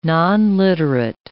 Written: nonliterate

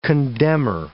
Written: condemnor

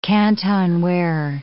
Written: Canton ware

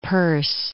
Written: Peirce

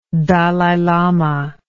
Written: Dalai Lama

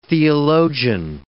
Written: theologian

